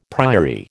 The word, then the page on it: priory: American English